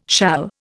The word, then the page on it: shall: Indian